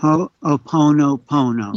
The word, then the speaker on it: Ho'oponopono: So Ho'oponopono, how you say it? – Ho'oponopono.